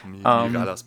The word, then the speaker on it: Mirallas: Es wird wahrscheinlich Miraya oder sowas aussprechen. – Ach, Mirallas passt schon.